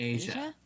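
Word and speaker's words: Aja: How would you say that, the name of that Steely Dan record? – Asia.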